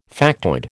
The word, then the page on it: factoid: American English